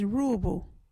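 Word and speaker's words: Ruubu: Our local Wadjuti word is Ruabu.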